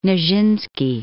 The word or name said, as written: Nijinsky